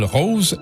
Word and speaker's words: Rauze: Elle s'appelle Rose et ça s'écrit R-A-U-Z-E.